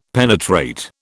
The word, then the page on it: penetrate: British English